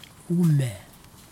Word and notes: ume: kwamera

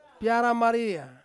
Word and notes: piara maria: Ansus

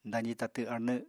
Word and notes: dazi tatw arnw: apatani

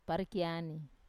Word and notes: parekiani: wamesa

